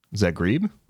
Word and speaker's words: Zagreb: Zagreb, yeah. Say that again, please. – Zagreb? Did I pronounce it wrong? – A bit, yeah. Zagreb.